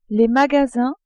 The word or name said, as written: magasins